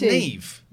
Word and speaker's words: Neve: But it's pronounced Neve.